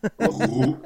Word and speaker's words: Rouen: Ähm, der der Mini-Abschnitt, den ich am schönsten fand, war, wie, wie spricht man diese Stadt aus? R-O-U-E. – Paris. – Nein. Rouen.